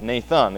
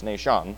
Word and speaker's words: Nahshon: Phares begat Hezron, and Hezron begat Ram, and Ram begat Amminadab, and Amminadab begat Nahshon," and that's Nahshon, I'm not mispronouncing that. It's not Nathan, it's Nahshon.